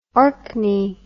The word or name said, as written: orkney